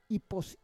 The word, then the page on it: ipos: Roon